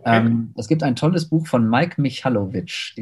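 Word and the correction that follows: Michalowicz: Ähm, der Michalowitz. – Michalowitz, okay.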